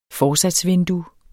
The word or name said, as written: forsatsvindue